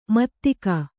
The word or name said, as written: ಮಾತಿಗ